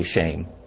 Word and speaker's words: shem: The Hebrew word for name is shem. We pronounce it shem, but it's really shame.